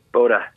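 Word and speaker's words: Boddah: We're going to leave with, I think it's pronounced Boda? – Boda. – Yeah. Boda.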